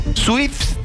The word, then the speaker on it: SWIFT: SWIFT, para pronunciar mejor, de Independiente Avellaneda para levantar la inhibición por Facundo Zavala.